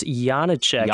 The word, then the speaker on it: Janáček: It's Janáček. And every time I listen to your podcast, I'm reminded that we have to work on this. – I'm just a Texas boy. At least I don't say Janáček.